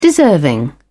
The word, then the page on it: deserving: British English